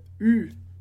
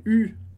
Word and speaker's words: eu: Lembrando que esse u, e, u, a gente pronuncia só com o u, que é o verbo avoir no passado.